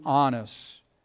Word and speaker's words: Annas: John chapter 18, verse 13, we read that first they led him to Annas.